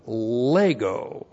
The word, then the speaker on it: lego: And then we have lego. L-E-G, long O, lego.